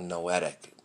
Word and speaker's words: noetic: and we have a noetic dimension. Noetic rhymes with poetic.